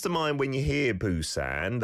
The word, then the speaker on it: Busan: You can still find it from time to time, spelled P-U-S-A-N, Busan. And a B, I suppose, it's closer to a B, I would say, in terms of the sounds, but it's not a million miles away from a P either, depending on which vowel sound it is paired up with.